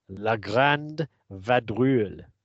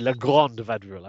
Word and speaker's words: La Grande Vadrouille: Our first film of Season 6, Episode 1, Weekend at Crombie's, will be La Grande Vadrouille. Sorry, La Grande Vadrouille. I've mispronounced.